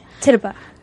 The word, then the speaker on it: Tituba: Say it fast. Tituba. – Tituba. There we go.